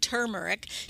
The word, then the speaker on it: turmeric: Which is spelled T-U-R, like turmeric, T-U-R-M-E-R-I-C, is a root that looks a lot like ginger root, except it's orange on the inside.